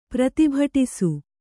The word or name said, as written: ಪ್ರತಿಭಟಿಸು